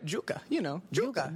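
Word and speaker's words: yuca: And I remember the street I was staying in was Yucca Street. – Right. – But in Puerto Rico, in Spanish, we pronounce it Yucca.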